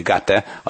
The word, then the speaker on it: gate: A Kár Johans Gate az a legfontosabb utca, és hát így ejtik, hogy Gate a norvég németesebb az angolnál.